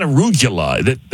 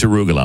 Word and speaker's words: arugula: But man, it's arugula. It's not arugula. He is adding syllables.